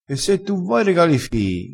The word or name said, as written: regala fii